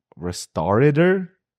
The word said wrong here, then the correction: restaurateur: This is the actual pronunciation. Restaurateur.